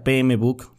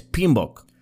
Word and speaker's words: PMBOK: O alguien que ya supo cómo se pronuncia, o si alguien lo pronuncia PMBook, tú dile, no es PMBook, es Pinbook. Ok,